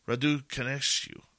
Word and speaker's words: Raducanescu: Raducanescu. Raducanescu, or is it Raduc? No, the syllable will be on the second. Raducanescu.